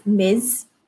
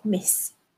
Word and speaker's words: Ms.: That is pronounced as Ms. Basically, it sounds like you spell it like this M I Z. So Ms. instead of Miss.